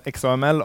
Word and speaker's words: XAML: Uttalas XAML av många. Microsoft som har skapat SAML uttalar det SAML. Därför gör jag det idag.